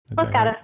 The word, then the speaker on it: Los Gatos: Am I saying that right? Los Gatos? – Yeah, Los Gatos.